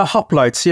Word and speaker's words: hoplites: This is the famous hoplites, am I pronouncing it correctly? – The hoplites, yeah.